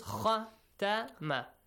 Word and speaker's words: ختم: In recitation, there is an important point, Fawad and Al Nasir, pay attention, and that is to make sure you do not change a short vowel sound to a long vowel sound, and also a long vowel sound to a short vowel sound. For example, if it is khatama, you don't have to say khatamallahu, khatamallahu, or khatamallahu.